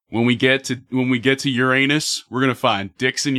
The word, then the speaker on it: Uranus: All right, I am Rob Bartholow, creator of My Cock Enters Uranus, and I believe it's pronounced Uranus. – Yeah, you're right. My cock is going to urine us.